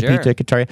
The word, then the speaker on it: Nepeta cataria: The leaves of this plant, Nepeta cataria. Am I saying this right, Graham? – Sure. – Nepeta cataria.